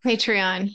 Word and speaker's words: Patreon: Patreon.